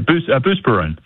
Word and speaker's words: Buspirone: I mean, she was on a boatload of Buspirone. Am I saying that correctly? Probably not. – Yeah, Buspirone. – Buspirone.